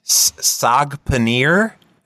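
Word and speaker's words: saag paneer: And sag paneer, is that, did I say it right? – Yes, and that is one of my favorites.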